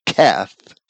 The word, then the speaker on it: Heth: It's TS, the Zadi character, and then CH, which is the Heth character, which is properly Keth, and then the Kaf, or the Q.